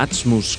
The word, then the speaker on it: Atzmus: como señalan esta banda Atzmus, que, bueno, significa en hebreo esencia, aunque como lo pronuncian los askenazíes.